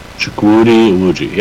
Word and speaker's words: Chukwudi Iwuji: Chukwudi Iwuji.